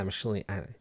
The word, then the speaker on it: Michelinie: So for the Web of issues, the writer was David Michelinie? Is that how you say it? – Or Michelinie. – Yeah, Michelinie.